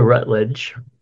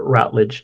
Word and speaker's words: Routledge: Actress Dame Patricia Rutledge, or Routledge, depending on how you want to pronounce it, who basically played Hyacinth's bouquet in Keeping Up Appearances, died at the age of 96.